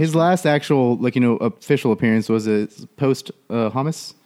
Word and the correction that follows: posthumous: How's that word pronounced? – Posthumous? Posthumous? – Posthumous. – Posthumous. – Right?